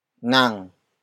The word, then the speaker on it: nang: Sha is spelled as S-I-Y-A. It means he or she. The focus of the sentence. The next word is nang. Nang. Nang. Nang is spelled as N-A-N-G.